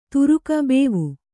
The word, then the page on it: ಭವ: kannada